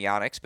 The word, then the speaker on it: Yonex: People say Yonex, but just so you know, it is Yonex.